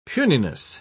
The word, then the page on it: puniness: British English